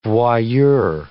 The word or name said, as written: voyeur